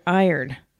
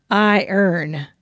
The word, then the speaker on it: iron: I pronounce it phonetically, I-ron, and she pronounces it like a second language, I-earn. So, if you can solve this argument, we would be grateful. – That is obvious to me. It's iron.